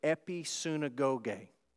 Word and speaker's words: episunagoge: The Greek word here for gather is the Greek word episunagoge. Episunagoge. Sounds like the word synagogue, right?